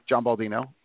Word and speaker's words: John Baldino: And the way that people say my name when they say it rather quickly, John Baldino, sometimes would sound like Jumbo Gina.